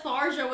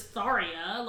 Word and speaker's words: Tharja: Like, I didn't know that Tharja was pronounced as Tharia. Like, no one knows that. But apparently Tharia is a Persian name, and that's how you're supposed to pronounce it.